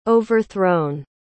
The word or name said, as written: overthrown